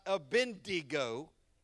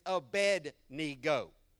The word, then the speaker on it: Abednego: His name is not Abednego, it's Abednego.